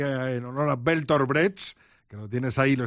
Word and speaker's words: Brecht: Hay que pronunciar Brecht, según me ha dicho alguien con sapiencia alemana.